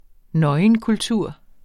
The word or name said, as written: nøgenkultur